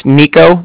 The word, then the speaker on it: Niko: My name's Nico. That's N-I-K-O. – N-I-K-O, uh-huh.